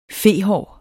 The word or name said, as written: fehår